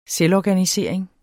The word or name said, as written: Selvorganisering